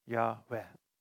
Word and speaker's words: Yahweh: J-H-W-H. Waarschijnlijk uitgesproken Yahweh.